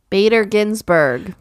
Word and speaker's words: Bader Ginsburg: I butchered it last time. So Bader Ginsburg. – There we go. I said it correctly.